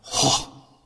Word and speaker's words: Gogh: You see, our deeply religious and talented person is none other than, and I'm going to pronounce this very correctly, Vincent van Gogh, okay? Not go, not Gogh, Gogh, because that's the province he comes from in Holland. They pronounce his name Gogh from the throat, not from the nose.